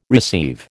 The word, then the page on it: receive: American English